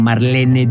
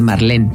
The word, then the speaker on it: Marlene: como Marlene Dietrich, por ejemplo, que todo el mundo dice Marlene, pero es Marlene.